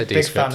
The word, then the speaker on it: Descript: Descript, I think. – I say Descript.